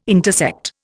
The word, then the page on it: intersect: Indian